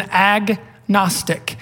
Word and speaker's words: agnostic: We say somebody is an agnostic. We don't use the silent G. We pronounce it.